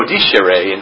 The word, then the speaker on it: discere: By the way, the word discipline, just before we get started, comes from the Latin disco, discere, or disco discere, and I pronounce it the church way.